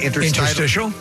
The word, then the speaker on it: interstitial: Interstitial.